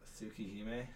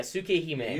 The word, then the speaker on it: Tsukihime: Tsukihime? – Yeah, Tsukihime.